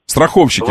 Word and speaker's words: страховщики: Страховщики, вот. Да, страховщики, правильное ударение.